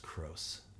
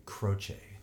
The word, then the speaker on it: Croce: Actually, I don't think his last name is pronounced Cross. I think it's A.J. Croce.